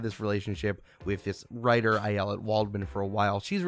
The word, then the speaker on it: Ayelet: Ayelet, yeah.